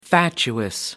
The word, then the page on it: fatuous: American English